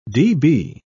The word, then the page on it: db: British English